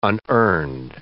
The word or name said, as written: unearned